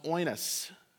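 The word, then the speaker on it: oinos (οἶνος): Our new wine or wine is spelled, the wine that does not have the ferment in it is spelled O-I-V-O-C. It's pronounced oinos.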